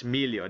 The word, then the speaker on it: Miglio: Oh, it's Miglio. The G is silent.